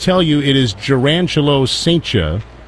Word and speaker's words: Cijntje: Gerangelo Saintia's last name is spelled C-I-J-N-T-J-E. Pronounced Saintia.